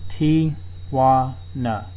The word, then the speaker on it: Tijuana: Tijuana.